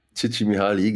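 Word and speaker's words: Csikszentmihalyi: Ja, man könnte auch sagen, das geht so in die Richtung von der Glücksforschung, ja, von Mitch Mahaly. Ich habe den Namen jetzt bestimmt wieder falsch ausgesprochen, aber ich finde Mihaly. – Chichi Mihaly, genau.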